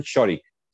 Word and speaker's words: Čorić: Bornan, creo que es Borman Čorić. Ah. Suena C-O-R-I-C, pero se pronuncia Čorić.